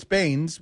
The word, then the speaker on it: Baines: You know, it's like you're going from Drummond to Bane. – Well, it's not just that, though. I'm saying it's Baines with an S.